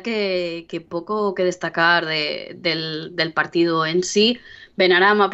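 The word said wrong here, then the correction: Benrahma: parece que está Benrama. – Siempre lo digo mal.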